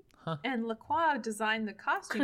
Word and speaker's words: LaCroix: Apparently that is pronounced LaCroix. – Oh, God. – I've discovered, because I looked it up online after someone said that I was being really pretentious by pronouncing it LaCroix. And I was like, it's LaCroix, darling.